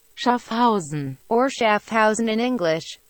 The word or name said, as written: Schaffhausen